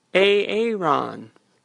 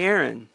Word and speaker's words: Aaron: Hello, this is Aaron. Or you can call me Erin. Welcome. You're listening to Erin Tra Radio. That's spelled A-A-R-O-N-T-R-A.